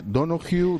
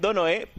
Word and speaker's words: Donohoe: Que depende, porque hay algunos que dicen Donohue porque él es irlandés. Entonces, como el apellido es irlandés, al parecer la H con la E sí que es verdad que habría que pronunciarla. Habría que decir algo así como Pascual Donohue, algo así como muy suave, ¿no?